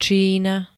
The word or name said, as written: Čína